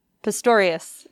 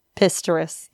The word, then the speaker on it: Pistorius: Pistorius. – Oscar. Pistorius. – He's the Blade Runner. – Pistorius. – Pistorius.